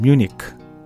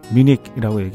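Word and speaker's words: Munich: 뮌헨 우리가 뮌헨이라고 하죠. 미국에서는 뮤니크 뮤닉이라고 얘기하고 독일에서는 뮌헨, 뮌헨 이렇게 얘기해요.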